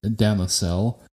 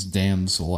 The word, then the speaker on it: Damosel: And so the book was titled Cain's Damosel. That's spelled D as in dog, A-M-O-S as in Sam, E-L. I thought it was Cain's Damsel at first.